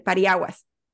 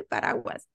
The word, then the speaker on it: paraguas: Yo decía, por ejemplo, había alguien que decía, ay, es que es el pariaguas. Yo decía, así no se dice, se dice paraguas.